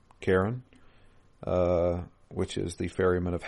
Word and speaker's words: Charon: Karen. Isn't that the ferryman? That Karen. Apparently, it's pronounced Karen. Karen. I know it's weird.